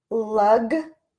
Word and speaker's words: luggage: Luggage. And even though this looks like age, age, like what age are you? We pronounce it with an I sound in this particular word, luggage.